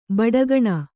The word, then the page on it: ಬುಡಿಗಾನೆ: buḍigāne